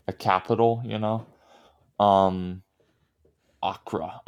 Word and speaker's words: Accra: And Accra. Okay. I, I am pronouncing that wrong, probably. – Yeah, Accra. that's fine. Accra.